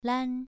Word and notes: lan: 2

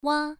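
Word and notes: wa: tone 1

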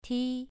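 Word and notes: ti: tone 2

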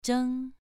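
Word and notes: zheng: tone 2

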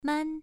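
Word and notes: man: tone 1

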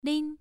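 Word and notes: lin: tone 1

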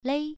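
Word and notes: lei: tone 1

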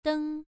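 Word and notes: deng: tone 1

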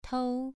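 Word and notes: tou: tone 2

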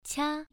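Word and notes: qia: tone 1

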